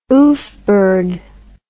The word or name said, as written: oofbird